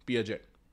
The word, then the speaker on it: Piaget: पियाजेट, PIAGET, पियाजेट।